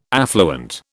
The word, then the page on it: affluent: British English